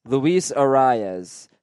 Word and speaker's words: Arias: It it it comes out sounding like one syllable, but there is a subtle mouth movement where you where you pronounce the second vowel very slightly. – Okay, Luis Ariz, Luis Arias.